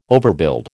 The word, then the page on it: overbuild: American English